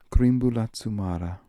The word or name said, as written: Kreemboolazoomara